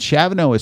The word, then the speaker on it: Chavane: And Chavano is spelled C-H-A-V-A-N-E. And do not ask me why it's pronounced Chavano when it's spelled like Chavane or something.